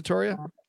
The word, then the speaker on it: Toria: Is it Toria? – Yeah.